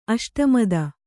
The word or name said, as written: ಅಷ್ಟಮದ